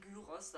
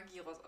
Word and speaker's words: Gyros: Ja, ich habe mit 25 Jahren habe ich erfahren, dass man Gyros sagt mit Ü, mit betontem Y. Und ich habe es einfach immer Gyros ausgesprochen.